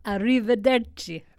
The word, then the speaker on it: Arrivederci: Arrivederci. Roma? Was I close? – Arrivederci. – I can't do the accent. – Gotta roll them R's.